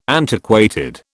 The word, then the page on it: antiquated: British English